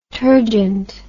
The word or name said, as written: turgent